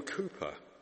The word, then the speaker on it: Cowper: Now, I call him William Cooper. It's spelt C-O-W-P-E-R, but I call him Cooper.